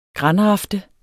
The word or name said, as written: granrafte